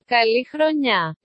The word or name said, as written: kali chronia